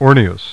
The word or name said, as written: Orneus